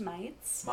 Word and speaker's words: Mites: It's pronounced Mites. – Mites. – Like the bug.